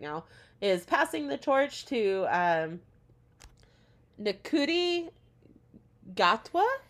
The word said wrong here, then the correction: Ncuti: It's pronounced Shuti. From a video that I saw, but Shuti Gatwa is how you pronounce the name. – Okay, I never would have guessed considering it's spelled N C U T I.